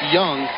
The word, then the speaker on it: Iung: And by the way, Joe, when you get to your roster sheet, Joe Young, that's spelled I-U-N-G, but it's pronounced Young, he finished with 23 points and 8 assists.